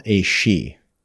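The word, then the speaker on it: Aos Sí: And it also relates to these fairies or spirits that they called Aoshi. It's spelled A-O-S-S-I.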